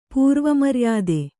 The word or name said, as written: ಪೂರ್ವಮರ್ಯಾದೆ